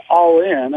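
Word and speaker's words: Ollin: So, again, with greatness, passion, and all in. I mean, it's actually pronounced almost like it's spelled A-L-L-I-N, although it's O-L-L-I-N.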